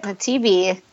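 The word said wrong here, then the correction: Naviti: I think no. it's Navidi and Malolo.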